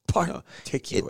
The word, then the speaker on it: particular: With the accent on the P-A-R. Like it was particular.